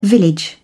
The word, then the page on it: village: British English